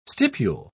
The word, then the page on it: stipule: British English